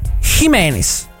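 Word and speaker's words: Jiménez: In difesa Jimenez, ecco anche su Jimenez, ragazzi, ne ho sentite dire di tutti i colori, Jimenez, no ragazzi, è spagnolo, si scrive con la J, si pronuncia Jimenez, via, non storpiate il suo cognome, se no è come se storpiassero il vostro.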